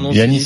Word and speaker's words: Giannis: Oui, mais en grec, ça se prononce Giannis. – C'est un prénom grec.